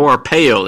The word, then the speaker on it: Arpaio: But that story involved the sheriff, Apayo, how do you pronounce his name? Sheriff, the famous sheriff, the anti-immigration sheriff, in Maricopa County. What's the guy's name? Joe Arpaio, that's his name.